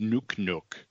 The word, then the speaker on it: Nuknuk: His name was spelled N-U-K-N-U-K, pronounced Nook Nook.